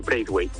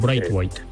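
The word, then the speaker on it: Braithwaite: Bright White, lo he dicho bien? – Sí, yo pronuncio Bright White, pero vamos. Sí, Bright White.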